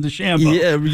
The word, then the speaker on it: DeChambeau: Your guy, Rochambeau, had a really nice weekend. – You mean Bryson DeChambeau? – Yeah, Tyson. – Right, Rochambeau. – Tyson Rochambeau. – She can't pronounce it.